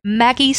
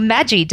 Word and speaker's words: Maggi: And also, this is where they were introduced to Maggi sauce, or Maggi, depending on how you would like to pronounce it.